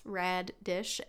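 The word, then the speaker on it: Raddish: The Rad Dish box. – Rad Dish. – Uh-huh. It's spelled radish. Radish, yeah. All one word. But it's Rad Dish.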